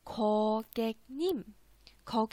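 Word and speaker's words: ko ge nim (고객님): 叫做 ko ge nim，ko ge nim 啊，ko ge nim。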